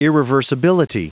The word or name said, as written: irreversibility